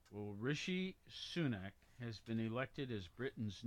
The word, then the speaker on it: Rishi Sunak: Yeah, I just looked at the pronunciation as well, and it's Rishi Sunak.